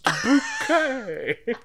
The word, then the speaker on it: Bucket: I think it was obviously you pronounce it different to how it's spelt. – Oh, that's reminded. – And then I was just like, it's pronounced bouquet.